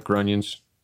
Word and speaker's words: Grunions: Like I like Grunions because it's, it rhymes with Funyuns.